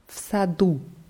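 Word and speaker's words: Fsadu (в саду): Und es hat jetzt einen Konsonanten am Ende, also im Garten würde heißen Fsadu.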